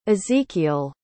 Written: ezekiel

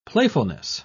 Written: playfulness

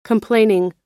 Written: complaining

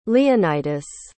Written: leonitus

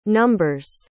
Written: numbers

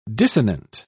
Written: dissonant

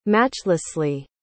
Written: matchlessly

